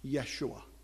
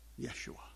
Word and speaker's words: Yeshua: more like it was pronounced. And so you'll hear them refer to Yeshua. Yeshua.